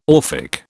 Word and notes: Orphic: British English

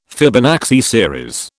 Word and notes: Fibonacci series: British English

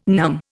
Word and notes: numb: Indian

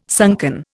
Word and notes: sunken: Indian

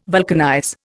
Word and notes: vulcanize: Indian

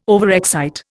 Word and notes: overexcite: Indian

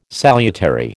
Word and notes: salutary: American English

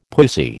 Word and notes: pussy: American English

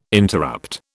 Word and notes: interrupt: British English

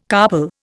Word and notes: carpal: Indian

